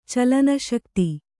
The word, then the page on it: ಚಲನಶಕ್ತಿ: calana śakti